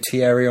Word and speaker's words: Thierry: Yeah, but you don't say Terry Henry, do you? You say Thierry Henry.